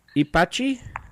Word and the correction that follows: Ipatinga: Ipatinga. – Ipatinga? – That's it.